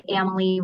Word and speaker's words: Amalie: We always say Amalie rhymes with family. So there you have it. – It's just that easy, yeah. Right? Amalie like family.